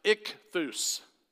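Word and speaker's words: ichthus (ἰχθύς): It's pronounced ichthus.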